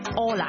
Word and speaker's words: Hola: 无论你在哪个拉美国家都啱用的打招呼的方法，就是最简单的 Hello，但在西班牙文呢 H 呢是永远都是不发音的，我们就会读 Hola。